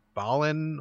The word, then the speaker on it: Balan: This is called, excuse me if I'm pronouncing this wrong, it's called Balan, B-A-L-A-N, Balan Wonderworld? – Yep. I think it's Balan Wonderworld.